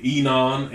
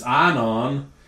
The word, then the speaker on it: Ainon: I've always pronounced them Enon and Salim. And that's not how they're pronounced in Greek. I'm not a Greek scholar by any means whatsoever. But according to Blue Letter Bible, which I always go for my pronunciations to make sure that I'm trying to say them right. Anyway, it says it's Ainon and Salim is how they say to pronounce these.